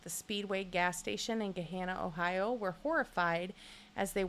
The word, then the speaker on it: Gahanna: Gahanna? – I guess. G-A-H-A-N-N-A. – Gahanna it is.